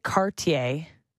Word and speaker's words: Cartier: Ty Cartier, I had to make sure that's how you say it because it looks like Cartier, listen to the goal call.